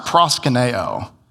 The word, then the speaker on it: proskuneo: It's pronounced proskuneo.